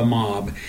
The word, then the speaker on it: MAHB: The acronym is MAHB, and it's pronounced the MOB.